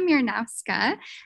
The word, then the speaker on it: Miernowska: This is one of my Dear teachers and mentors and friends and amazing human beings of this world, Marysia Miernowska.